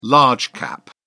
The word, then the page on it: large cap: British English